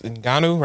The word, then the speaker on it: Ngannou: I think the boy, Francis Ngannou, right? That's how you pronounce his last name.